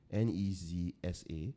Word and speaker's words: Nezsa: Her name is N E Z S A. So I don't know how to pronounce it. But I think it's Nezsa.